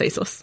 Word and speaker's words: ASOS: Yeah, it's ASOS.